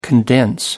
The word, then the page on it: condense: British English